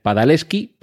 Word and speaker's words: Padalecki: Va a ser en su lugar el actor Jared Padalecki, que como se escribe con C y con K al final, y probablemente tenga un origen polaco, eh, se debería de pronunciar Jared Padaleski, pero en fin, vamos a dejarlo ahí.